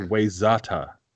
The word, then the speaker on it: Wayzata: Because it's spelled Wayzata. W-A-Y is not Y. Spell Y. No, spell Y. It's W-H-Y. – W-A-Y. Z-A-T-A. – Wayzata.